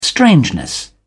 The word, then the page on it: strangeness: British English